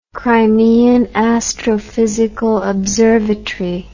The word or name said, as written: Crimean Astrophysical Observatory